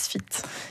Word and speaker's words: SFIDT: Non, ça s'écrit S-F-I-D-T. – D'accord. – Ça se dit Sfit.